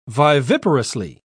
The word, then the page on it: viviparously: British English